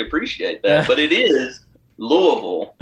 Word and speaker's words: Louisville: Ooh, he attended the University of Kentucky, which possibly explains why he fully pronounces both S's when he says Des Moines, but can't seem to find the single S when he pronounces Louisville.